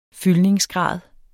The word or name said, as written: fyldningsgrad